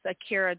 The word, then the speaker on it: Ahkyra: That's Akira Jackson. Akira is spelled A-H-K-Y-R-A.